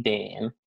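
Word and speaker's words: Dayne: Sure, it's a D-A-Y-N-E Dane, and then the last name is Shuda, S-H-U-D-A.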